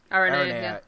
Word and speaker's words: Aranea: Like, Ariana and stuff like that. But, Ariana. – She's Aranea. – Yeah. I knew I pronounced it wrong.